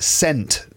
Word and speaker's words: scent: Because it's S-C-E-N-T. Now, if the S is silent, if it's just C-E-N-T, then that's pronounced cent as well, like in money, right? In America, you've got dollars and cents, 50 cents, right? But if it's S-E-N-T, if the C is silent, it's still sent, isn't it?